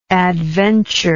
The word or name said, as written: adventureful